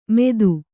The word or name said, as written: ಮೇದು